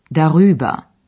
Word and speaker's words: darüber: Następny przykład dotyczy przyimka über, czyli darüber. Dodane w środku r ułatwia wymowę.